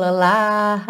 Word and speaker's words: alive: Cuidado com esse E no final, hein? Não importa o quanto você estica esse A, alive sempre fecha no som do V, porque esse é do final é mudo, percebe que ele dá tá pintadinho de cinza para você não pronunciá-lo.